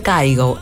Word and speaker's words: Kygo: Sí, acá en Argentina le dicen Kygo, pero en realidad se pronuncia Kygo. Es noruego, así que ya saben, Kygo.